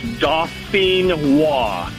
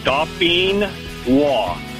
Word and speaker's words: Dauphinois: All right, so pronounce that for me one more time so I don't sound like a complete idiot when I order them. – No problem. It's, like I said, it's French. It's Dauphinois. And Dauphinois.